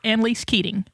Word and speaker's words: Annalise Keating: Is it Annalise Keating? – Perfect. Annalise Keating.